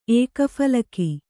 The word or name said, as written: ಏಕಫಲಕಿ